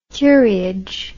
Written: curiage

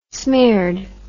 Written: smeared

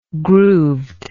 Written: grooved

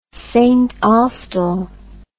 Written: saintaustell

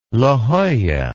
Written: La Jolla

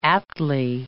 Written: aptly

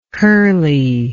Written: curley

